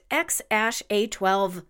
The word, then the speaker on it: X Æ A-12: Musk said in a podcast that the name is pronounced X-A-12 Musk.